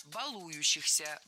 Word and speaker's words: балующихся: Балующихся. Неверно. Правильно. Балующихся. Балующийся. В этом причастии, как и в глаголе, от которого оно образовано, ударение не ставим на корень. Баловать, балуешь, балующийся.